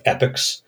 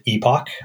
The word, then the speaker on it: epoch: Well, I can rewind the clock, and it has a concept of of epics. You'll hear you will hear folks pronounce it epoch. I like to call it an epic.